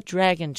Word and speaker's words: DRAGEN: It sounds like dragon, but no, it's spelled DRAGEN, the dragon chip.